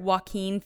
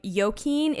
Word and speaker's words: Joaquin: Joaquin Phoenix's name on Tuesday's episode. So my apologies for continuously throughout the episode calling him Joaquin. It is actually Joaquin Phoenix.